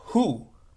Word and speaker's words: Hu: And lastly, there's a mantra which in Arabic is known as Hu, H-U, which is pronounced like an elongated breath.